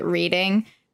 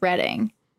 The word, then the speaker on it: Reading: I think things that bother me, it's more like I grew up in Reading, Pennsylvania, and everyone always pronounces it Reading, and people pronounce the Reading Railroad on Monopoly, the Reading Railroad, but it's Reading.